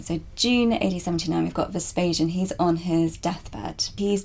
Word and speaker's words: Vespasian: Let's go to your first scene, which is in June 79 AD, with the death of Vespasian. Am I pronouncing that correctly? – Vespasian, yeah.